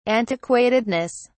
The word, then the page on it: antiquatedness: British English